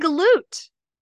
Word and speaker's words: galoot: I put a G at the end of it. – Galoog, okay. – Big galoog. But I'm saying it correctly now. The big galoot.